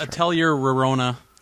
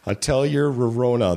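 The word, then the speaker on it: Atelier Rorona: It's Atelier Rarona. – Let me make it sound so easy. Atelier Rarona, the Alchemist of Arland.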